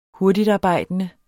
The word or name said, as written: hurtigtarbejdende